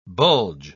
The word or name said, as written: bulge